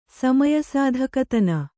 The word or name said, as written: ಸಮಯಸಾಧಕತನ